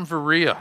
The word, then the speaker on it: Berea: So now we've got Silas and Timothy coming down from Berea. That's how they pronounce it in Greece, you see.